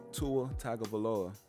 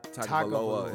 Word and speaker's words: Tua Tagovailoa: Tua Tagovailoa. – Tua Tagovailoa. – Yeah, Tagovailoa.